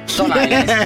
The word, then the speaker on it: Stone Island: Questi jeans della Stone Islands. – Stone Island. – Ma perché scrivono? Scrivono come si legge. – Si chiama così, Stone Island.